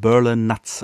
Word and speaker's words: Berlinutz: Jetzt kann ich es auch richtig aussprechen. Berlin Nuts, also Berlin, Berliner Nuss auf Deutsch quasi.